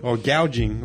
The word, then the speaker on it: gouging: Now that it's legal, they're gauging the motherfucking audience. – They're gouging. How do you say gouging, right? Gouging.